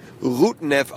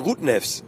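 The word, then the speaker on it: Rudņevs: Das erinnert mich so ein bisschen natürlich auch an unseren Spieler Rudnev, der definitiv Rudnev ausgesprochen wird, ohne das S, aber alle sagen Rudnevs.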